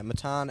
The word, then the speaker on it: Matan: Yeah, Matan, M-A-T-A-N, but it's pronounced like Baton, but M.